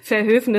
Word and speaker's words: Verhoeven: Zenta Bergers bürgerlicher Name ist übrigens seit 60 Jahren Verhoeven. Hoffentlich habe ich es richtig ausgesprochen. Liebe Grüße. – Verhoeven ist richtig.